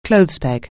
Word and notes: clothes peg: anglais

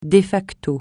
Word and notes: de facto: francais

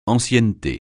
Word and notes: ancienneté: francais

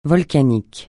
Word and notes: volcanique: francais

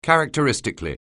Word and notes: characteristically: anglais

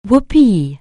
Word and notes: whoopee: anglais

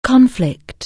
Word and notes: conflict: anglais

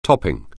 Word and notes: top: anglais

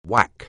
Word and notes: whack: anglais

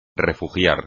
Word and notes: refugiar: espagnol

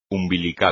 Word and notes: umbilical: espagnol

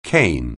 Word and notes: cane: anglais

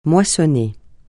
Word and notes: moissonner: francais

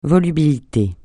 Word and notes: volubilité: francais

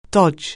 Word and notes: dodge: anglais

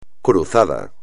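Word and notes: cruzado: español

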